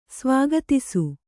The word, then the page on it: ಸ್ವಾಗತಿಸು: svāgatisu